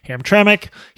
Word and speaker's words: Hamtramck: Hamtramck.